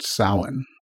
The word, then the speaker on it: Samhain: But Halloween really has its origins with a Druid feast called Samhain. Now, it's spelled S-A-M-H-A-I-N, so it doesn't sound any way the way it's spelled. But that's oftentimes the case with Celtic spelling.